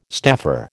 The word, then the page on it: staffer: American English